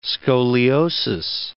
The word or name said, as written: scoliosis